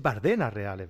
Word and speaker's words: Bardenas Reales: Y, no lleva tilde en la A. Yo siempre he dicho Bardenas Reales, que, y es Bardenas Reales, ¿verdad?